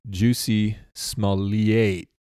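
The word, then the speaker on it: Jussie Smollett: It's pronounced Juicy Smolliate.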